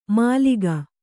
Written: ಮಾಲೀಕ

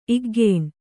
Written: ಇಗ್ಗೇಣ್

